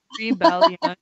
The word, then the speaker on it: rebellion: I will pass and say that rebellion, we can count as three syllables. Like the yun is one syllable. – Yeah, rebellion. It is. It is three syllables.